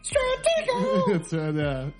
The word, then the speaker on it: Stratego: Stratego. It has to have three syllables.